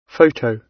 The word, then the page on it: photo: British English